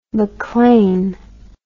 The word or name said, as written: maclean